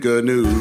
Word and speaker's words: gnu: Stop saying gnu. You're saying it wrong. Leave out that silent consonant.